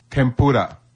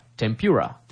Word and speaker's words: 天ぷら: 天ぷら。 – 天ぷら。あ、綺麗な発音で言えましたね、うん、ごめんなさい、バカにするわけじゃないんですけど私がよく聞く外国人の天ぷらっていうのは例えば tempura とかですよね。